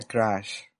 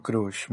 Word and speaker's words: crush: Y, y hay que aclarar, hay que aclarar que se pronuncia crash, no crush. Mucha gente le dice crush y no.